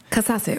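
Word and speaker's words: Kazaze: Der Treuebonus wartet immer noch bei uns und in der Zwischenzeit hat sich jemand gemeldet, hat uns aufgeklärt, dass es nicht Katasse ist, wie wir es ausgesprochen haben, aber wir waren uns da einfach nicht sicher. Haben wir nicht gewusst, wir haben wieder dazugelernt. Wird jetzt richtig ausgesprochen? – Katasse und ist natürlich die slowenische Bezeichnung von Edling.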